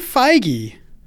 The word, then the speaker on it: Feige: Kevin Feige, and that is how you say it. Fi as in sci-fi, gi as in hard G.